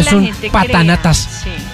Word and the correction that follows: papanatas: es un papanatas. ¿Qué? ¿Cómo se dice? ¿Patanatas o papanatas? – Papanatas.